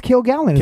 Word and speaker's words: Kilgallen: I think it's Kilgallen. Kilgallen. It's spelled like the word kill and the word gallon.